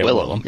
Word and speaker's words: Willem: Gene Hackman, William Defoe, Willem Defoe? How do you say it? Willem, right? – Yeah, Willem. Yeah.